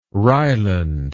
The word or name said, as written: ryeland